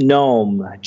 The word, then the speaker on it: Gnome: He's got something called Lead Gnome, G-N-O-M-E, Lead right.